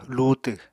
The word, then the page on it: luutw: Apatani